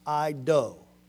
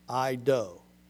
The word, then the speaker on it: eido: E-I-D-O, it's pronounced I-do. I-do.